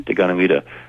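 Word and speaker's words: Deganawida: A lot of it had to do with one man, kind of cross between George Washington and Jesus Christ, how do you pronounce his name? – Daganoweda. – Daganoweda. – Actually, there's sort of different Iroquoian languages, and his name, you know, comes out differently.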